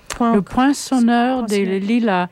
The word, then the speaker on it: Le Poinçonneur des Lilas: Just after an announcement, we'll have Serge Gainsbourg with Le Pinceur. Wait, how is it pronounced? – Le Poinçonneur des Lilas.